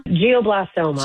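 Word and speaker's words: glioblastoma: They're another nonprofit that's dedicated to funding innovative adult and pediatric brain cancer research, particularly focusing on, how do you say it? Geoblastoma? – Geoblastoma. – Geoblastoma.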